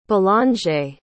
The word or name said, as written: bellangere